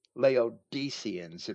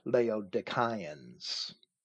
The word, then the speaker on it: Laodiceans: Laodiceans it might be pronounced in the modern churches. The Greek pronunciation is actually Laodiceans.